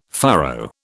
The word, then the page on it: furrow: British English